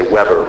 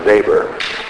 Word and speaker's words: Weber: We would say Weber, but you pronounce it in German, Weber.